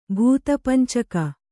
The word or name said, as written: ಭೂತಪಂಚಕ